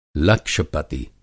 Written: लक्ष-पति

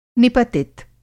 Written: निपतित

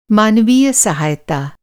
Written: मानवीय

